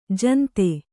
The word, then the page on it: ಜಂತಿ: janti